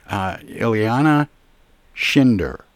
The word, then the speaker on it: Ileana Schinder: And we're going to talk about how that came about and what that all means with the author, Ileana Schinder, and she joins me by phone.